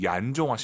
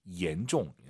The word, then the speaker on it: 严重: 혹시 지금 마음속으로 얀종 하신 분 계십니까? 중국어의 얀이란 발음은 없어요. Y를 I로 바꾸세요. I-A-N, E-N입니다. 실제로 한글 이로 시작하는 발음이에요. 얀이 아니라 얀, 얀종.